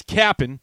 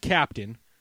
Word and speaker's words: Cap'n: That's Cap'n, not Captain. So make sure you spell it C-A-P-apostrophe-N.